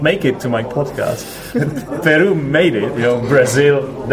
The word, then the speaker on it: Peru: Now, we should actually remind our listeners that the stress in the word Peru is on the second syllable, so we say Peru. Instead of Peru.